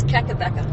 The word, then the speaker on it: Kakabeka: Which is saying it wrong. – What is it? – It's Kakabeka. – Kakabeka? Kakabeka Falls. Is that better? – Yeah.